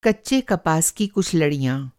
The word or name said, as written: लड़िया